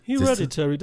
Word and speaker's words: Hereditary: Ich sag mal, wenn ich jetzt total abgenudelt bin und es ist noch nicht abends, sondern nachmittags und ich hätte mir Hereditary angeguckt. Oh, ich glaube, ich habe es jetzt richtig ausgesprochen, ne? – Ja, Hereditary, das ist man, ja quasi hier man, auch.